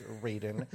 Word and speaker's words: Raiden: And yes, I know in Japanese that would be pronounced Raiden, but for some weird reason in Mortal Kombat, it is officially pronounced Raiden, which is, yes, obnoxious.